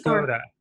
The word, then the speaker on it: Escoda: Did you say Escoda or started with a V? E? – Escoda. – Oh, Escoda. Oh, I tried to say it.